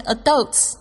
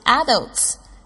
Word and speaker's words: adults: 你要注意一下这个 adults，它也有另外一个发音是 adults，但是呢，这个就是跟我们之前说的这个成熟的熟，有人会说熟，有些人会说熟一样。